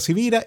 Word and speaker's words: Sivira: Mi nombre es Carlos Javier Sivira y como estoy claro que mi apellido no es así tan común, pues se escribe con S de semana, se escribe con V de Victoria.